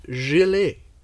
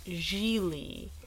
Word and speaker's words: Gigli: So, for this premiere episode of season two, we're going to be bringing you a funny little film called Gilet. – I, I believe it's pronounced Gigli.